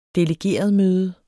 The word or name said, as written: delegeretmøde